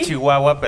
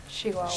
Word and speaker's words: Chihuahua: Ah, no, pero ella es de Chihuahua, es de ah, Parral. – Se escribe Chihuahua, pero se dice... – Chihuahua. – Chihuahua.